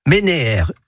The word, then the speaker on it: ménère: Hein, alors ça se prononce ménère. Il y a deux œufs. Et ménère, pas ménir.